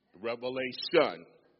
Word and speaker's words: Revelation: There's no S on the end of that word. Revelation rhymes with one.